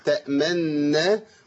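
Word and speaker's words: تأمنّا: يعني اصل كلمه تامنا تامنونا ولتخفيف النطق قرأ النبي صلى الله عليه وسلم تامنا وقرأ النبي صلى الله عليه وسلم ايه باختلاس.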